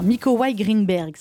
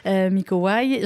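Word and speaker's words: Mikołaj Grynberg: Alors, on va voir si je le dis bien. Miko Waj Greenberg. Ça va, Miko Waj Parfait. – Parfait. Nicolas en français, mais vous êtes polonais. Miko Waj.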